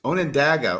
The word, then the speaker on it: Onondaga: And Onondagas, Onondaga or Onondaga.